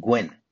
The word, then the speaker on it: Gwenn: It's with Edmund Gurn. Gurn? I think that's how you pronounce his name. Edmund Gwenn, who played Santa Claus in Miracle on 34th Street.